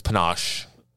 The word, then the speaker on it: panache: Is it called panache? – I think it's panache.